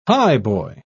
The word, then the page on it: highboy: British English